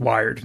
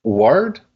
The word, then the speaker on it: Wiard: In the sense that we all should know that his name is pronounced Wired now.